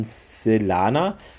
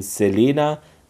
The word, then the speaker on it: Selana: Hayling erwähnt im Gespräch mit Weir und Bates praktisch diese Athosianerin Selana. Während das im Englischen auch so ausgesprochen wird, ist es in der deutschen Fassung geändert worden zu Selena oder Selina.